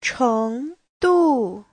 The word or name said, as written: cheng2du4